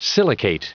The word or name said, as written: silicate